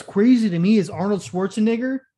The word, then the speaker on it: Schwarzenegger: It's Schwarzenegger. – Yeah. That's a long A.